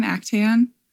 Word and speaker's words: Actaeon: Actaeon.